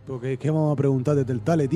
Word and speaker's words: Telltale: Sí, porque al principio lo has dicho bien y ahora has cagado. – Telltale.